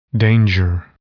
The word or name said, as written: danger